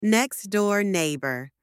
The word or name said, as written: next-door neighbour